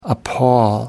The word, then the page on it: appal: American English